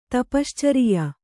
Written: ತಪಶ್ಚರಿಯ

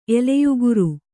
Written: ಎಲೆಯುಗುರು

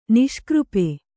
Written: ನಿಷ್ಕøಪೆ

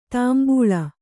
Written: ತಂಬಳಿ

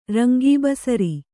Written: ರಂಗೀಬಸರಿ

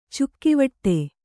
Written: ಚುಕ್ಕಿವಟ್ಟೆ

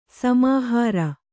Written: ಸಮಾಹಾರ

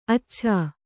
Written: ಅಚ್ಚಚ್ಚೆ

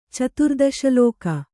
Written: ಚತುರ್ದಶಲೋಕ

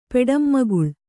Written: ಪೆಡಂಮಗುಳ್

